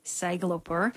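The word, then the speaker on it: Sæglópur: It's it translates to mean lost seafarer, but it's pronounced Sæglopur.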